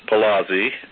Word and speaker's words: Paulozzi: Frieden, that's spelled F-R-I-E-D-E-N. Following his remarks, Dr. Frieden will be joined by Dr. Leonard Palazzi, that's spelled P-A-U-L-O-Z-Z-I,